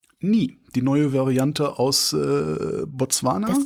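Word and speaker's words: Ny: Es wird zumindest als griechischer Buchstabe ausgesprochen, habe ich im Internet gelesen. Das heißt nie. – Ah, okay. – Wie die Ritter, die immer nie sagen. Nie, nie, nie.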